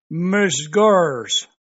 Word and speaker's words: Misgurrs: Oh, Ms. Gors.